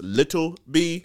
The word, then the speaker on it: Lil B: And I also want to say, Lil B, they need to be very particular how they pronounce his name. It's Little B.